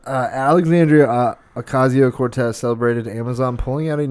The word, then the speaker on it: Ocasio-Cortez: I call her Oscario Cortez. – I don't know how to pronounce it either. – I don't know. AOC, what is it? Her name is Ocasio Cortez. I think Oscario is pretty good.